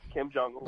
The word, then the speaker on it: Kim Jong-il: So when he's talking trash or to Kim Jong-il, is that how you pronounce his name? Kim Jong-il. – Kim Jong-il.